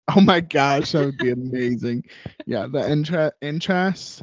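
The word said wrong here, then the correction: entr'acte: Is that how you say it? Entrace. – The entract. – Yeah, is that into Act Two? – Bows.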